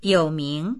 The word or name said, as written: you3ming2